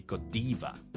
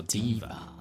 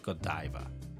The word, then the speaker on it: Godiva: So uh, they pronounce these words more in English. So it will be Godiva. – Oh. – Yes. Godiva. Godiva. – Uh-huh. Yes. Not Godiva.